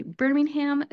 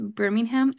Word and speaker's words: Birmingham: Now, I know Birmingham is a city in England and they pronounce it very differently, but here in the United States, we say Birmingham, so it is Birmingham, Alabama.